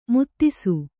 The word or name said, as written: ಮದಿಸು